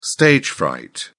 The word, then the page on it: stage fright: British English